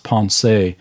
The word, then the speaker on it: Pensée: He wrote in a collection of thoughts, a book called, we call it sometimes Penzance, or I think in French it's pronounced Pensée.